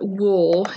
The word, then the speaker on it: Wuhl: Robert Wool, is that how you pronounce it? W-U-H-L? Okay, yeah.